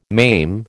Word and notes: maim: American English

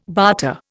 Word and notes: barter: Indian

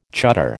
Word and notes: chuddar: American English

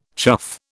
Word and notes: chuff: British English

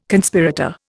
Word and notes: Conspirator: Indian

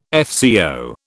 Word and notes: FCO: British English

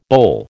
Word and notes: boll: American English

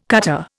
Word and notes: cutter: Indian

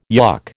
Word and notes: yuk: American English

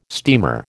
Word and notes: steamer: American English

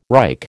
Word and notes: Reich: American English

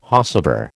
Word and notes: whosever: American English

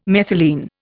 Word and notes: methylene: Indian

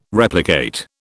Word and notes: replicate: British English

